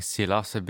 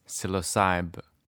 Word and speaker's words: psilocybin: And I'm saying psilocybe because I'm in Canada. And really, I've learned that like the age-old Canadian accent pronounces it as psilocybe. But for those who listen to a lot of American speakers, it's psilocybin.